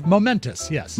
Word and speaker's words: Momentous: Now, am I pronouncing that correctly? – Momentous, yes.